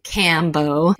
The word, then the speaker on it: Kambo: Kambo medicine, so it's spelled K A M B O. A lot of people think it looks like or is spelled like the combo meal. It's not spelled like the combo meal. It's the cambo is a way that certain people pronounce it.